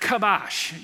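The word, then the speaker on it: kabash (כבש): The word there for subdue is an interesting Hebrew word called, it's pronounced kabash. You've heard that word before.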